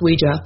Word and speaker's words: Ouija: And they said, well, they called it Ouija, although it's pronounced Ouija.